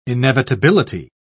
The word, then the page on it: inevitability: British English